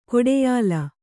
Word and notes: ಗಾಢಾಯ್ಲ: gāḍhāyla

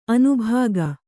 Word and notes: ಅನುಭೋಗಿ: Kannada

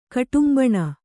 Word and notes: ಕುಟುಂಬಿನಿ: kuṭumbini

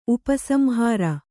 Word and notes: ಉಪಸಂಹಾರ: upa samhāra

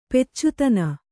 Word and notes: ಪೆಚ್ಚುತನ: peccutana

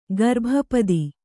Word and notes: ಗರ್ಭಪದಿ: garbha padi

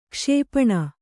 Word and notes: ಕ್ಷಿಪಣಿ: Kannada

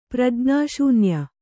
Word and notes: ಪ್ರಜ್ಞಾಶೂನ್ಯ: prajñā śunya

